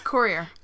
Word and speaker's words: courier: Just because, like, it's French, right? – Yeah. – Like, things, you don't pronounce things, you know? – Yeah. But in English, it's courier.